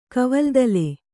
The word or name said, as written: ಕವಲ್ದಲೆ